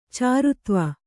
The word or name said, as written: ಚಾರುತ್ವ